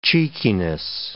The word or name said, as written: cheekiness